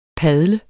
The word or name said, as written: padle